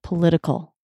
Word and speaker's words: political: How do you think the T is pronounced there? Political. Political. Political. – I think that's a T sound with a little bit of a D.